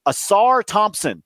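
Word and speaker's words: Ausar Thompson: Asar Thompson. Did I say it right? – Oh, yes.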